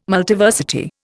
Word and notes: Multiversity: Indian

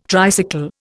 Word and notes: tricycle: Indian

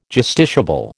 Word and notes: justiciable: American English

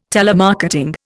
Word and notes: telemarketing: Indian

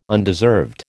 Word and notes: undeserved: American English

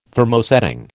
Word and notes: Thermosetting: American English

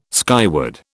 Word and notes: skyward: British English

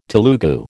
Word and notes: Telugu: American English

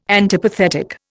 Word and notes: antipathetic: Indian

